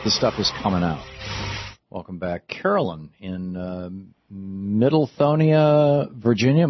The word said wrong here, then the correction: Midlothian: Midlothian. – Midlothian.